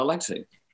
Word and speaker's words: Alexei: Yeah, Alexei. – Okay. – Yeah, A-L-E-X-E-I.